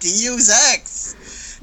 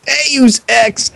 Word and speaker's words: Deus Ex: I said it correctly. It was correct. I said Deus Ex. – No. – Deus Ex. – No, it's Deus Ex.